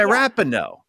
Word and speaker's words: Rapinoe: Yeah, Rapinoe.